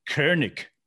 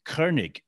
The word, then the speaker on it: Koenig: So the way you pronounce, for instance, Walter Koenig is really Koenig. Koenig in German.